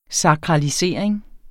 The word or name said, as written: sakralisering